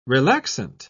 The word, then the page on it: relaxant: British English